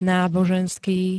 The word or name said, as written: náboženský